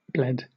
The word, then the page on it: bled: British English